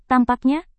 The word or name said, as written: tampaknya